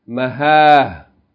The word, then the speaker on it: Maha: Pause mode, you pronounce it Maha. As though it's a ha on the end with no vowel. Don't pronounce the vowel. When you stop on a word with Dharmabhuta, you don't pronounce its vowels. Okay, so you're going to pronounce it as though it's an H on the end. You're going to say Maha. Maha.